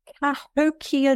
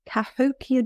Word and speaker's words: Cahokia: Cahokia Jazz. Cahokia Jazz. C-A-H-O-K-I-A Jazz.